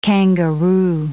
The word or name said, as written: kangaroo